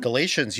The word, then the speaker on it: Galatians: Okay, another pronunciation. Forgive me. Galatians. – Mm-hmm. Galatians, you got it.